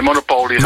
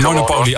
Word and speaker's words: Monopoly: Zeg jij Monopoly of zeg je Monopoly? – Nee, Monopoly. – Monopoly, oh, gelukkig wel. Nee, dan zeg ik het goed.